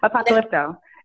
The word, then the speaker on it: Apocalypto: Apocalypto.